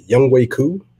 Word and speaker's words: Younghoe Koo: And we signed a part ways with Falcons legend Matt Bryant, and we have signed Younghoe Koo. – Thank you for saying his name right.